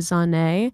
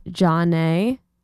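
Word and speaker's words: Zhané: Then you heard Hey Mr. DJ by Zane, pronounced Jaune, from pronounced Jaune, released in 1994.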